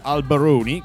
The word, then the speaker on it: Albarone: Questa canzone è scritto Albarone, lui lo pronuncia come avete sentito Albaroni, che in realtà è un gioco di parole che ricorda una catena di bar inglesi, All Bar One,